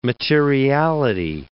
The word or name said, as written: materiality